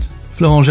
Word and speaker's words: Florent: featuring Volkor X, and I don't know how to say this person's name, Florent? – Yeah, yeah, you're right. Florent Gerbeau.